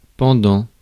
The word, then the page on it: pendant: France; /pɑ̃.dɑ̃/